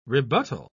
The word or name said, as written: rebuttal